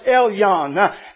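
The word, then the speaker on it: Elyon (עליון): That name by Hebrew Hebrew definition is pronounced Elyon.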